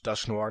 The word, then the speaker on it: Dusknoir: So Dusknoir, I've heard it being pronounced Dusknoir. If anybody knows any French at all, I used to speak French, not fluently, but close enough. So Dusknoir is, it's pronounced Dusknoir, not Dusknoir, unless they've said something different in the show.